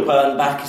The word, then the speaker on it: Bernbach: Because I, I, I, saw you quoted Bill Bernbach, is that how you pronounce his name? – Yeah.